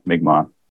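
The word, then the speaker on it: Mi'kmaw: It's one of those words. Yeah. Yeah, for sure. People pronounced it incorrectly. So yeah, Mi'kmaq, but spelled M-I apostrophe K-M-A-W, Moons.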